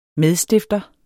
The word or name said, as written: medstifter